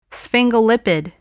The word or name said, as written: sphingolipid